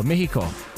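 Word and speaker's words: México: Oh, Mexico. – Okay. Oh, yeah, why you on. Say it that way? – Yeah. That's how they say it.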